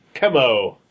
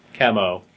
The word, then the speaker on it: Chemo: That he got infected by fighting Chemo. – Yeah. – And not chemo. – Not chemo. – As I had pretty much always thought it was pronounced.